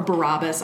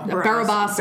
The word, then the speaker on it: Barabbas: Or Barabbas. I don't know. Barabbas. That's how they pronounce it in Passion of the Christ.